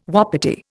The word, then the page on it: wapiti: Indian